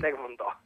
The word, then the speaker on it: taekwondo: il taekwondo, che si dice con l'accento,